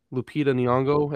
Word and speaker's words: Lupita Nyong'o: Lupita Nyong'o.